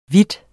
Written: hvid

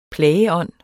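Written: PLAGEÅNDEN

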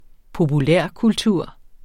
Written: populærkultur

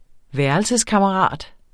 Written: værelseskammerat

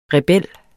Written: REBELLERNES

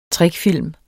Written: trickfilm

